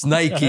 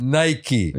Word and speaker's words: Nike: It's Nike. – Yes. – Okay. – And what did you tell me? I said it correctly. – Yes. – All right. And you fucked it up. – Nike.